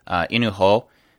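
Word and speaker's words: Inu-Oh: If you guys get a chance to check that out, it's spelled I-N-U hyphen O-H. Inu Ho.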